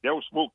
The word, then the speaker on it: Deus Vult: if I was started reading a book called Deus Vult. Am I pronouncing that word right, General? D-E-U-S-V-U. – God, yes. Exactly. Deus Vult. That's it.